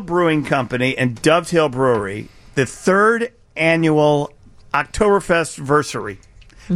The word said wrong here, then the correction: Oktoberfestiversary: It's Oktoberfestiversary. – Yeah. – I'm missing an is sound. I'm missing an entire syllable right there.